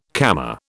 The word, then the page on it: Kama: British English